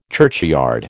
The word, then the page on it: churchyard: American English